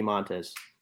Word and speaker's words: Montas: Um, Frankie Montas. – Right. Did I pronounce it right, Bruntz? – Yeah, that's right.